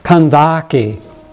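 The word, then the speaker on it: Kandake: He was the treasurer of Candace, it says in our New Testament text. In the original, it's three syllables, Kandaki.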